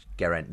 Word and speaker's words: Geraint: That's with a friend of yours, a colleague, agnostic physicist Geraint Lewis. Am I pronouncing it correctly? – Yes, he's Welsh.